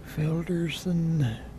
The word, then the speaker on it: Felderson: Felderson.